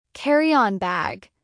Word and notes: carry on bag: American English

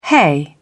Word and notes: hay: British English

